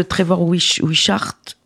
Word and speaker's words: Trevor Wishart: Est-ce que tu peux nous parler de Trevor Wishart, si je prononce bien? – Oui, tout à fait bien.